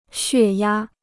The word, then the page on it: 血压: xuè yā